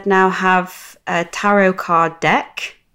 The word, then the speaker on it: tarot: Do you say tarot? – Yeah. – I say tarot. Am I saying it wrong? – I think so. Shall I Google it? Shall I Google it? – Yeah. How to pronounce that? – Pronunciation. – I don't want to say it wrong. – It's all right. It's like the third highest suggestion on Google. So is it really? – Yeah, you're not the only one. Yeah, it is a silent T at the end.